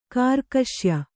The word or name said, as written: ಕಾರ್ಕಶ್ಯ